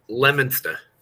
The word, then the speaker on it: Leominster: It's Leominster.